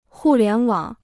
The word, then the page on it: 互联网: hù lián wǎng